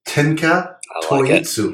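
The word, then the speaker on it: Tenka Touitsu: And I'm going to pronounce this correctly, you motherfuckers, because I studied Japanese for 3 years in college. And it is Tenka Toyitsu.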